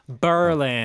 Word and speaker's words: Berlin: I've been to Berlin many times for speech tournaments, and not that it matters, but the good folks of Berlin, Wisconsin pronounce the town with the emphasis on the burr instead of the lin. Berlin.